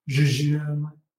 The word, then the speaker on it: jejune: it's not even pronounced correctly per, correct pronunciation is Jeju, the hard J. And I'm like, no, no, no, soft J.